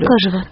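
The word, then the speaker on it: Экажево: Правильное ударение ставлю? – Екажево.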